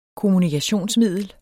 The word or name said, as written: KOMMUNIKATIONSMIDLET